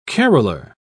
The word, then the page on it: caroler: British English